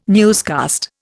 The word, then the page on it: newscast: Indian